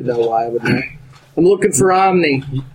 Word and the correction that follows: Omi: Omi. – Or Omi, sorry.